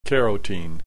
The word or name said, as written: carotene